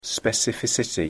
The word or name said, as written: specificity